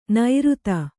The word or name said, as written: ನೈಋತ